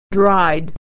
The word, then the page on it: dried: /ay/; American English